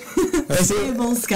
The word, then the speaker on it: Sabulsky: Sabulsky.